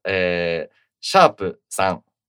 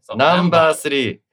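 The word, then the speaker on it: #3: シャープ 3、これシャープって読まないんですね、ナンバー 3、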